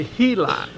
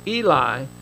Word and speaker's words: Eli: Now, some of your translations say Heli. Is that right? Some of you have it. And it's just in that translation, they gave it the Hebrew pronunciation. The Greek does not have an H in the alphabet. And so my translation says Eli, but some of them has given the Hebrew pronunciation starting with an H.